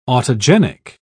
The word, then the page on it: autogenic: British English